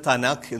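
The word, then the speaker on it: തനാഖ് (תנ״ך): അങ്ങനെ TNK എന്ന് പറയും. ഉച്ചാരണത്തിന് എളുപ്പത്തിന് തനാഖ് എന്ന് പറയും.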